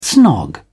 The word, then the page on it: snog: English; British English